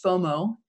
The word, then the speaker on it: FOMO: The initials are FOMO. It's pronounced FOMO, and it means fear of missing out.